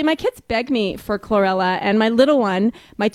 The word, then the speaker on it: chlorella: Well, first of all, I'm going to correct the pronunciation. It's chlorella is how you pronounce it.